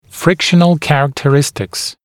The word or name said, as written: frictional characteristics